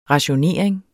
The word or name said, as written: RATIONERING